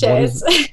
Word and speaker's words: Shez: But everybody pronounces it the non-French way. So they call me Shez and not Shay.